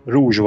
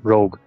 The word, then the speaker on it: Rogue: Még, még pár adással ezelőtt, talán kettővel, valahogy szóba jött a, a Zsivány 1-essel kapcsolatban, hogy néhányan a, az eredeti címét Rogue van helyett Rouge vannak ejtik. Még az én ismeretségi körömben is vannak pár, akik, akik ránéztek a címre, és kapásból gondolkodás nélkül úgy olvasták ki, hogy Rouge, pedig az Rogue.